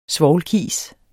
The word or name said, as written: svovlkis